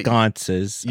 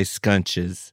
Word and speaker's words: sconces: Like that, sconces. Yeah. I say scunches.